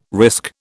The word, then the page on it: risk: British English